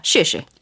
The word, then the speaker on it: 试试: 시시 너무 비슷하죠 발음이. 그렇죠? – 네. – 사성 경성으로 읽어 주면 돼요. – 시시.